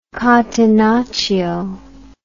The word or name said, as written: catenaccio